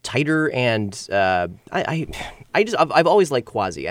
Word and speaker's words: Quasi: I get the feeling that Harlow's has plenty of great artists coming through the Starlet Room, and this next artist, Quasi, is it Quasi or Quasi? – I've always pronounced it Quasi. – Quasi.